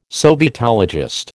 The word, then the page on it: sovietologist: American English